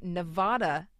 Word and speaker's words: Nevada: Outgoing Assemblyman Harry Mortensen is now working on a resolution that would make Nevada an equally acceptable pronunciation as Nevada.